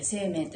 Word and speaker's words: 清明: サタデーモーニングライブは今ねちょうどシーミーの時期で、二十四節気でいうところの清明というね、清い明るいと書いて清明と読みますけれども、沖縄はこれをシーミーと言ってね、シーミー行事があるんですね。